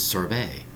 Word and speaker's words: sorbet: Today's word is sorbet. Sorbet, spelled S-O-R-B-E-T. Two syllables.